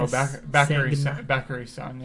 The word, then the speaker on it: Bakary Sagna: Oh, Bakary Sagna.